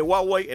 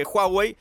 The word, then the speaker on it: Huawei: Así que, viste que en algún momento de la charla dije Huawei, en otro momento dije Huawei. Está bien de las dos maneras. No hay problema con la pronunciación de esta marca de origen chino que está creciendo mucho.